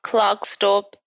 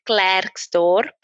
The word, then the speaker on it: Klerksdorp: Okay, so I am from Klerksdorp in the Northwest Province. People from Klerksdorp would know that that's not how you pronounce it. It is Klerksdorp, but okay.